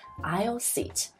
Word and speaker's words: aisle: aisle 它的拼写是 aisle，但是读的时候呢，这个 s 不要发音，不是 aisle 了，是 aisle seat。